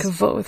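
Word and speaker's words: Kvothe: It is Kvoth.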